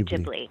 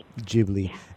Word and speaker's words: Ghibli: So Ghibli, and it's Ghibli, correct? – Yes, that's how the Studio filmmakers pronounce it, Studio Ghibli. – Ghibli.